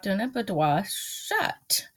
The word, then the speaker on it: boudoir: Boudoir.